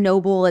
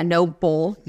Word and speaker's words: Nobull: That's like kind of the CrossFit-y brand, Noble. It's spelled N-O-B-U-L-L. Yeah, Noble.